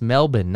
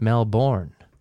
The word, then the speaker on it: Melbourne: Australian Kate, I believe, told me it was pronounced Melbourne, not Melbourne.